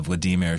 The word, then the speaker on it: Vladimir: In interviews, he says that the Vladimir should rhyme with redeemer,